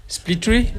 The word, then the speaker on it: Split Tree: Split Tree. – Ouais. – S-P-L-I-T. – Ouais. – Plus loin, T-R-2-E.